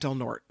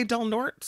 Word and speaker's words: Del Norte: Is that how the locals pronounce it? – It's the correct pronunciation. – No, but it's Spanish. It would be Norte. – No, it isn't. As a matter of fact, if you're talking about the BART station in the East Bay, it's Del Norte. But if you're talking about the county, right, they pronounce it Del Norte.